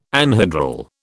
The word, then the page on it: anhedral: British English